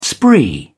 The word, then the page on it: spree: British English